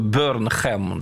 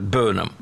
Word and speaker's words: Бёрнам: Здесь сразу объясню, кстати, нашим слушателям, что он пишется по-английски, если читать вот буквы Бёрнхэм, да? Но читается вот именно так, Бёрнам.